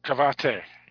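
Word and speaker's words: Kavaitė: It came out in 2015, directed by Alante Cavate. Hopefully I'm saying that right. And who also wrote the movie? – Cavate. Yeah, he's got it right the first time.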